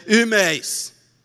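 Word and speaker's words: imeis (ὑμεῖς): Português é difícil fazer a confusão entre nós e vós, mas em grego, nós é imeis e vós é imeis. Você percebeu alguma diferença? Pois é. A pronúncia é quase idêntica.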